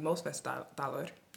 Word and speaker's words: Mosfellsdalur: And you mentioned several times about your archaeological studies and work that you've done here in Iceland, particularly in Mosfellsdalur, right? – Right.